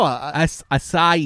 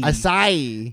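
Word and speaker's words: açaí: Do you know how to say it again? – I don't know. – Acai. – Acai. – Yeah, you got it.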